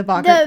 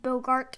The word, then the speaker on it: Boggart: Did you like the Boggart part? – Oh, you said it right. – I know. How did I say it every time I read it in the book? – You said Bogart.